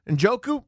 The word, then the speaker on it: Njoku: I haven't said his name right yet. The tight end. I believe it's Njoku. Do I have that correct, anybody? – Njoku. – There we go.